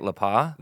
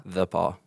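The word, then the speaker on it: The Pas: So how do we say it? La Paz? – The Paw.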